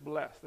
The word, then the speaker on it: blessed: The first trick is don't break the extra syllable off on the end like blessed. Yeah? You don't actually have to break the extra syllable. It's just blessed. That's how it flows.